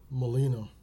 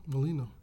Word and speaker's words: Mollineaux: Mollineaux. – Really? – Yeah, Mollineaux.